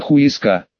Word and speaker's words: Хуэска: И вот ЕБП, только вошедший в гармонию с миром, оказался в испанской жопе мира под названием Хуэска. Нет, тупые испанцы не произносили первую букву, но ЕБП умел читать и на зрение не жаловался. Он ясно видел название городка, Хуэска, значит Хуэска, как звучит, так и пахнет.